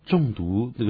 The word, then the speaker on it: 重读: 重读，那个重是重量的重啊，读是读书的读，可不要呢念成中间，然后呢读要的读，那还不得了。